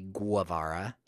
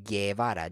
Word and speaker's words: Guevara: And he's not called Che Guevara. He's called Che Guevara, G-U-E-V-A-R-A. Guerra.